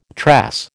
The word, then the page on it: trass: American English